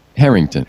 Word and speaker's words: Herington: John, is it Herington or Herington? How do you say it? – Herington. – Herington. – Yeah.